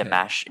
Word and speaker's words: Dimash: Dimash is spelled D-I-M-A-S-H.